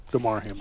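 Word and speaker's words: Damar Hamlin: I hate that you hate Lamar Hamlin. DeMar Hamlin. – He does not hate DeMar Hamlin. – I like him enough to get his name right. – I fixed it like right away.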